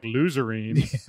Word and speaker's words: Luzerne: More like Luzerne.